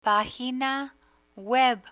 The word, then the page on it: pagina web: Spanish